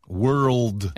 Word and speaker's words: world: 이거 발음 하나만 주의해 볼까요? WORD 단어란 뜻의 이 단어는요. – Word. – 네, word 이거고요. 그다음에 WOR 그다음에 L이 하나 더 있어요. – World. – 네, world. world 하면서 혀를 한번 탁 튕겨주시면 돼요.